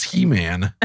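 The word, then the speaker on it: Heman: David and the officers of the army also set apart for the service the sons of Asaph and of He-Man. I don't know why I was struggling with pronouncing it. It's He-Man.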